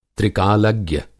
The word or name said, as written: त्रिकालज्ञ